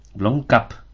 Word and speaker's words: Blomkamp: Eh, Neil Blomkamp, Blomkamp, perdón, Blomkamp, siempre lo pronuncio mal.